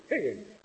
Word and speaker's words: Hegel: Hegel? How do you pronounce it? Hegel, as you say. Hegel.